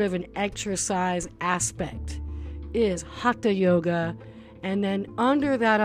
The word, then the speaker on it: hatha: I know for years I've been saying that word hatha, pronouncing the T and the H, but that's not so. Hatha yoga is the proper pronunciation of that word, and that is the yoga of movement or yoga of activity or to create heat.